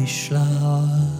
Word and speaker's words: Schlauer: And Mary Schlauer, who rhymes with flower, with a sh on the front.